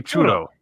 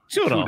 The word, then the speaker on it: churro: Well, you say it with a D though. You say churro. – Churro. Churro. It's the same thing. No. – You have to roll your R, yeah. So it's churro.